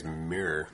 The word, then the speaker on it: Myr: And he's a Mire. I hope I'm saying that right. – I think it's Mirror. – Oh, yeah, that would make sense. Mirror. – Mirror, yeah. – I think it's Mirror, M-Y-R.